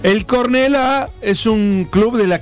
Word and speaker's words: Cornellà: Cornellà. – ¿Qué? – Con acento en la Cornellà.